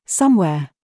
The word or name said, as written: SQUARE